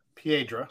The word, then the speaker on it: Piedra: Piedra. – So, because I already said it wrong and then apologized for saying it wrong and then corrected it thanks to your brother.